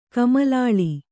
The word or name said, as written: ಕಮಲಾಳಿ